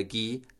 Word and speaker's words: gui: So the word for mistletoe is gui. – Gui. – Yeah, it's spelled G-U-I.